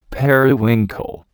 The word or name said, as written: periwinkle